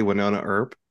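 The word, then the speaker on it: Wynonna Earp: Winona Earp.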